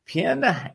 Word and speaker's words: Pynn: Pynn, and I, I, hope I'm saying that right, Chrystal. – Yeah, um, you're saying it right.